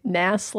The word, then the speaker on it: Gnass: Gnass, like Gnass, because the G is silent? – Yeah.